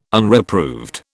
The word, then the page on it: Unreproved: British English